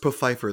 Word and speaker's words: Pfeiffer: Actually, always makes me think of the Golden Girls when they when they meet the funeral director, Mr. Pfeiffer, and he says, "Oh no, it's pronounced Pfeiffer. The P isn't silent."